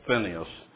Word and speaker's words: Phinehas: And here comes Phinehas, excuse me, Dave, you'd laugh about that. I pronounce it incorrect so I can spell it right, but it's Phinehas.